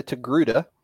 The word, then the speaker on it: Togruta: So Ahsoka is a Togruta.